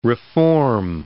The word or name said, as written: reform